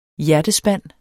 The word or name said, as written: hjertespand